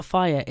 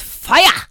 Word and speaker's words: Fiyah: And if you want to go and download it, I need to let you know that Fire is spelled F-I-Y-A-H. It's not fire, it's fire!